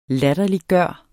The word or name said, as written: LATTERLIGGJORDE